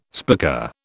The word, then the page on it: spica: British English